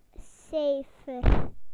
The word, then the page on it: number: British English